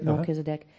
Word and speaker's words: Melchizedek: How does the Hebrews author know that Melchizedek, if I'm pronouncing that right? – Melchizedek? – Melchizedek.